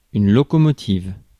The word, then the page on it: locomotive: Paris; [lo.ko.mɔ.tiv]; /lo.ko.mɔ.tiv/